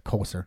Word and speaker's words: Coser: Tony Cossette, who is a guy who used to come, or Coser, that's how you say his name, who used to come into the Eclectic back in the day.